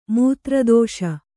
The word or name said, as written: ಮೂತ್ರದೋಷ